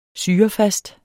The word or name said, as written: syrefast